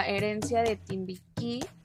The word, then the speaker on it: Herencia de Timbiquí: herencia de Timbiquí nos sí se pronuncia así verdad sí exacto